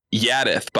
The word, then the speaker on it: Jaddeth: And then he, in an unbranded-like characteristic, goes, it's pronounced Jaddeth, by the way.